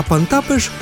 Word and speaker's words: Pontapés: А «пантапеш» — это тоже тычки ногой.